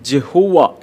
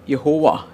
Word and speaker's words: യഹോവ: ഒരു നാമമാണത് ഇംഗ്ലീഷിലേക്ക് വരുമ്പോൾ ജഹുവ എന്നാണ് നമ്മൾ പറയുന്നത് എന്നാൽ യഥാർത്ഥത്തിൽ ഉള്ള ഒരു ഉച്ചാരണം യഹോവ എന്ന് തന്നെയാണ് എബ്രായ ഭാഷയിൽ